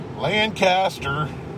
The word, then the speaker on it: Lancaster: Ah, oh, fucking good old Lancaster. – Lancaster. You said Lancaster.